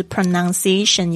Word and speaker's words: pronunciation: 这个字好像上一节课有讲，就是 pronunciation 有五个音节，大家不要偷懒只念四个。